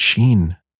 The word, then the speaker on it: Shin: Wow, it's Shin Ultraman, right? Like Charlie Sheen.